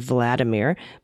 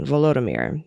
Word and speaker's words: Volodymyr: Volodymyr Zelensky would no doubt prefer if you did not pronounce his name in its Russian version, Vladimir, but the Ukrainian version, Volodymyr, as the long O's, like both, Volodymyr Zelensky.